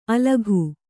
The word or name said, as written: ಅಲಕು